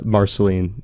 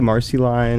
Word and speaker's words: Marceline: I just say it like the Marceline. But it, you know, people say Marcy Lane and people say kind of everything in between, and I it's all fine.